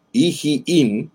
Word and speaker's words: Iim: Iim y Esem. Este Iim o Ijiim, como se pronunciaría en el hebreo,